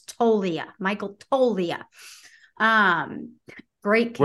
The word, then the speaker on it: Toglia: The most important thing I want to note here is the pronunciation of Michael Tolia. The G is silent.